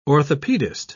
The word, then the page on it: orthopaedist: British English